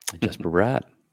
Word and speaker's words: Jesper Bratt: Jesper Bratt.